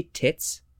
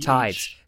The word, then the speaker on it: tithes: Did you just say tits? – Tits. T-I-T-H-E. – Tithes. Tithes. It's pronounced tithes. It's not pronounced tits.